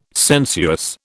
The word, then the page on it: sensuous: British English